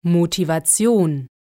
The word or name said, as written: Motivation